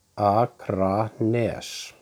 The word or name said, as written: Akranes